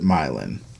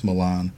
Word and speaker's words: Milan: There is also a Milan, Michigan. We also pronounce it myelin. – Mm. – We were talking about there's a myelin here in Tennessee. – Yeah. – It drives me crazy. It's Milan.